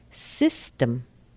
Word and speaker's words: system: Spelled with a T-O-M, but the sound is tum. Symptom. Or system. System. Spelled T-E-M, but the sound is tum. System. And a nice long word, tumultuous. Tumultuous. T-U-M, but the sound is tum.